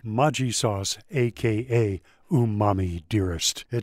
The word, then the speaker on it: Maggi: You know, I always wondered what this Maggi sauce is, which, by the way, is not pronounced Maggi. It's Maggi.